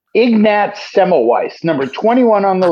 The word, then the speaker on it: Ignatz: That's how you pronounce that name. – The name is so good, Ignatz.